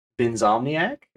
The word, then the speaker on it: Vinzomniac: Tonight we are featuring the creator of Pixel Pups, which is Vinzomniac.